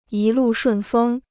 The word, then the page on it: 一路顺风: yī lù shùn fēng